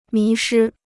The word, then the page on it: 迷失: mí shī